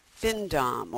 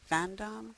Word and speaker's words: findom: And you also call yourself a findom or findom, financial dominatrix. I never know how to pronounce that. Fandom? – Findom. – Findom, okay.